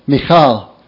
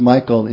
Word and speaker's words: Michal: Michal is her name. Sometimes pronounced Michael in English. Michal.